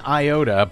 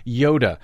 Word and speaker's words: Iota: Okay, Desi Doyen, I know that Americans are calling it Hurricane Iota, but everywhere else in the world, they're properly pronouncing it Hurricane Yoda.